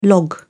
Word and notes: log-in: British English

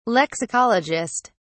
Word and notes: lexicologist: British English

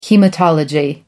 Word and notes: haematology: American English

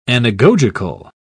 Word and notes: anagogical: British English

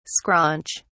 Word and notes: scraunch: British English

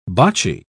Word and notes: bocce: British English

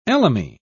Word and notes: elemi: British English

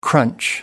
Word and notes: crunch: American English

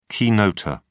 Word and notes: keynoter: British English

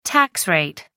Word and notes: tax rate: American English

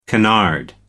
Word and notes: canard: American English

